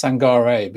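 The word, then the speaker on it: Sangaré: But what I will say with this one, and I'm, wait, just to go back as well, I'm pretty sure it's Sangare, because like someone's mentioned in the comments, and like I thought as well, there is an accent on the E at the end, I think.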